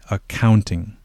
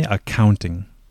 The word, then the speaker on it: accounting: So in this word, the important thing is to put the stress in the middle syllable, the count part, okay? So we say accounting. Okay, please repeat after me, accounting.